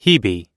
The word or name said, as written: hebe